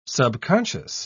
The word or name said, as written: subconscious